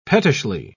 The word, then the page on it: pettishly: British English